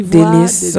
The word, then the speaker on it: Delice: Am I saying that right? – Ivoire Delice. So it's IVOIRE. Delice is written like DELICE.